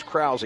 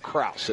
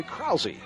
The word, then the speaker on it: Krause: Her mother came to see me before the game, and make sure you pronounce her name as Krause, not Krause. It's spelled like Krause, but it's actually Krause. K-R-A-U-S-E-Y. So we want to get that right.